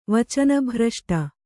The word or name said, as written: ವಚನಭ್ರಷ್ಟೆ